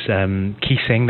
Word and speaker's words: Kising: Yeah, I quite like people to go to my blogspot, which is Keysing.